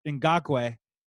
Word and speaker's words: Ngakoue: The Vikings, like you mentioned, and thank you so much for Ngakoue.